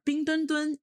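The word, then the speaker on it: Bing Dwen Dwen: So Mandarin, like, you know, standard pronunciation of the mascots' names are Bing Dun Dun and Xue Rong Rong.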